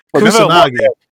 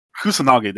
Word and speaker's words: Kusanagi: And I felt your pain when Kasunugi jumped. Oh my God, I can't pronounce his name at the moment. But Kasunugi. Oh my God. Holy shit. You know. – Kusanagi. – Kusanagi, there you go.